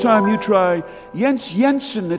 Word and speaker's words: Jens Jensen: Jens Jensen, spelled with a J, pronounced like a Y.